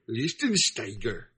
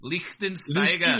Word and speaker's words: Lichtensteiger: No, dude, you got to say it right. It's Corey Lichtensteiger. – Lichtensteiger. – Lichtensteiger.